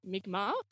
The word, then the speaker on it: Mi'kmaq: And one significant voice was this wonderful Mi'kmaq, which is spelled M-I-apostrophe-K. M-A-Q, Mi'kmaq, Native North American scholar called Uncle Terry LeBlanc.